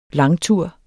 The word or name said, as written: langtur